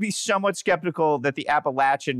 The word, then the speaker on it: Appalachian: Now they're saying it's Appalachian. I thought it was Appalachian. – It's Appalachian, man. – It's Latch. – It's Latch. – Lock it in your mind.